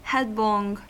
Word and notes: headlong: American English

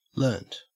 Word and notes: learnt: Australian English

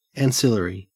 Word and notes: ancillary: Australian English

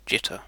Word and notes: jitter: British English

